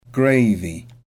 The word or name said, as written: gravy